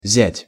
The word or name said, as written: zjat